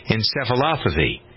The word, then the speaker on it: encephalopathy: Repetitive concussions, as you know, can cause CTE, chronic traumatic encephalopathy.